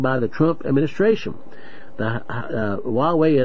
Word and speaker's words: Huawei: And it, to go to another further, you know, you've heard of H-U-A-W-E-I. How do you pronounce that, Melody? Huey? H-U-A-W-E-I. – Huawei.